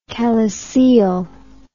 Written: calyceal

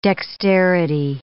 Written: dexterity

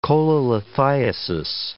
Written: cholelithiasis

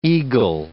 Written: eagle

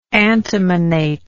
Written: antimonate

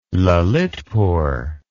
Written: Lalitpur

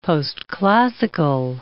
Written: postclassical

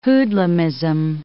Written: hoodlumism

